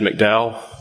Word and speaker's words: McDowell: My name is Chad McDowell. The last name is spelled M-C-D-O-W-E-L-L.